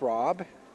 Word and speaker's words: Rob: N-O-N-A. – And Nona, where are you from? – Kentucky. – My name is Rob. – Rob, can you spell that? – R-O-B.